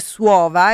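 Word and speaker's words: Słowo: Ringrazio Marco da Varsavia che mi scrive che il titolo della canzone che ho messo prima si legge Suova e significa parola,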